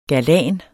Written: galan

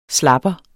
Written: slapper

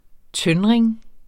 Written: tøndring

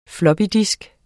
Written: floppydisk

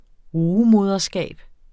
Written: rugemoderskab

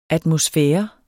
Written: atmosfære

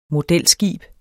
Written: modelskib

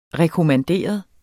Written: rekommanderet

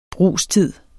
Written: brugstid

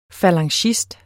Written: falangist

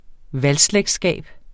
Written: valgslægtskab

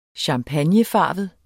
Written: champagnefarvet

